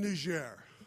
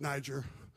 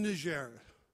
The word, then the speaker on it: Niger: I'm in Niger. Americans don't know how to pronounce it. They call it Niger. But I'm in Niger.